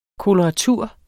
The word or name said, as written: koloratur